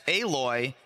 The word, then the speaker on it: Aloy: Is it pronounced Aloy? Oh, boy. Well, I didn't even get the first part. That wasn't even what I thought I said wrong. – We were asking about that second part. I wanted Mirrodin. I wanted to know if I said that right. That's so funny. But that makes sense. Aloy.